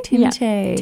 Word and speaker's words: Timothée: Which is the French pronunciation, I'll have you know. – Timothée.